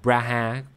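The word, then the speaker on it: Praha: Khi mà hoàng đế La Mã thần thánh Rudolf đệ nhị mời Grabel đến Bragg, này đọc là Braha, bạn Bragg, dù muốn ở lại Anh, ông không có lựa chọn nào ngoài chấp nhận lời mời.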